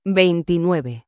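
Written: veintinueve

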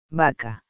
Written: vaca

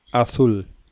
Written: azul

